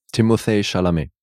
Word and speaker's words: Timothée Chalamet: Timothée Chalamet.